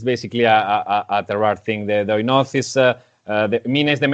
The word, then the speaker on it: Oinoz: And the name is Oinoz. It's spelled O-I-N-O-Z. – O-I-N-O-Z.